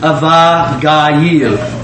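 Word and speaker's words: Abigail: Then said David unto her, it's Avagayil. That's how you say it.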